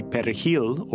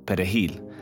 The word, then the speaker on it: Perejil: And so in Haitian Creole, the R's are not as, you know, you can't roll them as hard as a Spanish speaker. And so the thinking was, if he or she mispronounced Perejil, then they were taken away or killed on the spot.